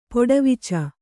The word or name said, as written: ಪೊಡವಿಚ